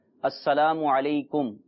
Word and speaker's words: السلام علیکم: تو سلام کس طرح کیا جائے، اس کا صحیح تلفظ یہ ہے، ہو سکے تو آپ دہرا لیں، السلام علیکم